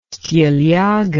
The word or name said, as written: stilyaga